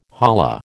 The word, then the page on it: holla: American English